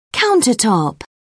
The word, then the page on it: countertop: British English